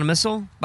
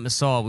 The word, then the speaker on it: Missal: Did you do Donna Missile? By the way, her name is Missile and not Missal. We've been saying this wrong this entire time.